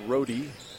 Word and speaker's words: Rode: R-O-D-E, pronounced Rohde.